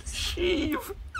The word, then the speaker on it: Sheev: Sheev? What the fuck? – Sheev Palpatine. – Is that how it's pronounced? – Yes. – Sheev.